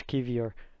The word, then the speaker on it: Kiwior: The real question is, how did you feel about Kiwior, or however you say his name? Kiwior? – Yeah, I think it's Kiwior.